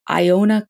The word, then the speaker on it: Iona: And then from 2003 to 2006, she worked at Iona College.